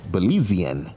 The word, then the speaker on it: Belizean: Wait, did I say that right? Belize? Belizean. That's how you say it.